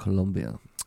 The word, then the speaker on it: Colombia: Colombia, yeah, it's actually pronounced Colombia. – Is it? – Yeah, that's actually how you say it.